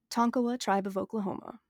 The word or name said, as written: Tonkawa